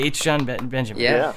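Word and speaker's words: H. Jon Benjamin: Yeah, H. John Benjamin.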